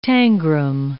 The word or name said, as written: tangram